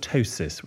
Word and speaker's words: ptosis: And I've got something called a ptosis with a silent P.